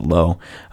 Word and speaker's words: Lowe: Brandon Lau spells his last name exactly the same, but pronounces it Lau. Nate pronounces it Lowe.